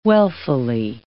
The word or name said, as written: wealthily